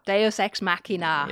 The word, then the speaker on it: Deus ex machina: The next one, which I'm going to mispronounce, Deus ex machina. – Deus ex machina.